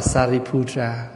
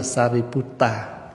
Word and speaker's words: Sariputra: Tiếng Phạn đọc là Sariputra, mà tiếng Pali là Sariputta.